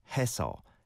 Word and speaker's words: 했어: So when it's got a double 시옷 받침 linked to an o on the next syllable, 했어, 했어. So the s sound carries over, whereas 했어 is only a 시옷 on the second syllable.